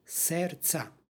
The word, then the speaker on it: srca: Također u vozglasu izgovaramo ocu i sinu i svijatomu duhu, dakle ocu prema u crkvenoslovenskom srce, srca, dakle, bez izgovornog d u ovoj reči, srca. U istoj molitvi, prvoj, pročitali smo za nje, sa nj.